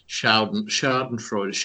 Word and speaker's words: Schadenfreude: Schadenfreude.